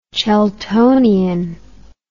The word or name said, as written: cheltonian